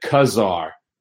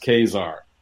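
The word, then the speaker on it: Ka-Zar: But the one I pronounced wrong was Kazar. I pronounced it Kazar my whole life as a kid. And I think when I got to Marvel, they said it's pronounced Kazar.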